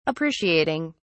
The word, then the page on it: appreciating: British English